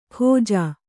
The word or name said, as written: ಖೋಜಾ